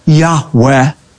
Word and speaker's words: Yahweh: whom Semitic scholars believe is pronounced a breathy, reverent Yahweh.